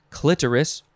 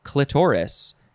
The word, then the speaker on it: clitoris: It can be pronounced a couple of ways. I think people probably argue one way or the other, but both are fine. It is clitoris or clitoris, C-L-I-T-O-R-I-S.